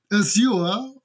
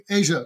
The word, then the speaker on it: Azure: Die Briten sagen Azure und die Amerikaner sagen Asia tatsächlich.